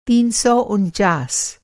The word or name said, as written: तीन सौ उनचास